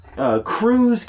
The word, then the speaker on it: Krewe: Krewes, K-R-E-W-E is how you spell it for those playing along at home.